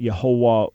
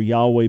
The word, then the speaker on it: Yahweh: So on an academic level, I reject the idea that Yehovah was the divine name and rather argue for Yahweh being the best and most likely pronunciation.